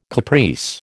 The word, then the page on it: caprice: American English